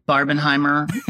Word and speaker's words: Barbenheimer: That's why on this episode, we can dig into all of it and talking about this Barbieheimer phenomenon. Is that even the right way to say it? Barbieheimer? I've seen people saying Boppenheimer. What do you say? – I say Barbenheimer.